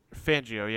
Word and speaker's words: Fangio: Fangio? Is that how you say his name? – Fangio, yeah. Like, I keep hearing it a couple different ways. – Like 104.3 the Fangio.